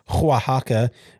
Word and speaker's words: Oaxaca: Very good pronunciation. – With sir. – A line. You like that? – I gotta say, you nailed it, because I'm like, oh, is he gonna get the Oaxaca?